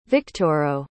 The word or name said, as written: victoro